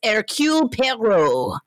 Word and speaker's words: Hercule Poirot: But I think it's also Hercule Poirot. – Yeah. – Well, I mean, if okay. Frasier says it's Hercule Poirot, but I didn't want to do that either.